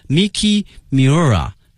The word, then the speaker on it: 三浦美纪: 后面看一下三浦美纪哈，他的日文的发音叫 Miki Miura， Miki Miura，但是呢他有一个笔名哈，大家更加的熟知啊，叫做 Momoko Sakura。